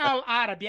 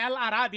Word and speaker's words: Al-Arabi: não é Al-Árabe, é Al-Arabi.